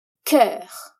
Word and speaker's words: cœur: C-O-E-U-R. É o exemplo típico de uma palavra que não pronuncia como se lê. E olha só! Aqui, a gente vai ter o mesmo som que encontramos na palavra olho, œil, mas agora com um som consonantal antecipado. Vamos lá, então. Cœur tem apenas uma sílaba, mas vamos pelos sons. O primeiro é da consoante C, que aqui vai ficar k, que também encontramos na palavra colis, d'accord?